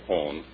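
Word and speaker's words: Hohn: It's H-O-H-N. Sounds like Hone. Long Island City, New York, is your good neighbor of the day. This letter is very short, but much to the point. Mrs. Hone is a nurse working 6 days a week.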